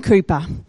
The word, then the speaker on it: Cowper: William Cooper. And it's spelled C-O-W-P-E-R, but we say Cooper.